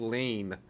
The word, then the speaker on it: Laine: Matthews or Line? Did I say it right? – To me, I always thought it was Lane. – Yeah, well, that's because that's how it's spelled.